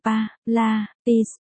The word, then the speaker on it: Pilates: 7, Pilates, đọc là Pilates,